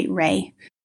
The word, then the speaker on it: Reay: My handle is at Emma Joy Reay.